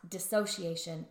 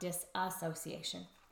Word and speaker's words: dissociation: So it's pronounced dissociation and not disassociation.